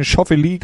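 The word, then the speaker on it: Schauffele: Du hast die Szenarien ja schon angesprochen, zum Beispiel Xander Schaufele, wo würden wir ihn deutsch aussprechen? Schaufele, glaube ich, sagen die Amerikaner.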